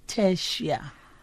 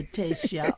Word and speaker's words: Tertia: My name is Tertia.